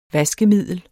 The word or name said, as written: VASKEMIDLER